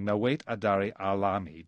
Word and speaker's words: Nawait Adari Alami: I wonder if we can listen to the very beginning of one song, Nawait Adari Alami. Did I pronounce that right, Sharifa? – That's fine. Yes.